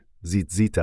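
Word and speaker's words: زیتزیت (ציצית): تلفظ زیزیت، زیتزیت است، و شکل جمع آن زیزیتوت یا به سادگی زیزیت است.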